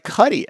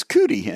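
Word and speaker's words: Cuti: And also, a note, the surname of E-Man's other co-creator, Nicola Cuddy, is pronounced like Cuddy, as it rhymes with buddy.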